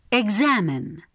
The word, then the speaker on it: Examine: The letter X is sometimes pronounced as g plus z. Repeat these examples. – Examine.